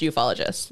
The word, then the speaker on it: ufologist: They say it's pronounced ufologist, like with a soft Y at the first.